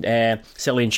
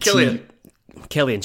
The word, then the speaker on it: Cillian: You could make the argument for Ryan Sweeney when speaking chilling Cillian Sheridan. Can still not pronounce his first name. And Cillian Sheridan. There we go.